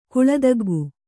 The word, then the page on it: ಗಲ್ಲತಗ್ಗು: kannada